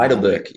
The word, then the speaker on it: Heidelberg: How do you say it? You say it better. – Heidelberg. Yeah, Heidelberg. That's a good pronunciation.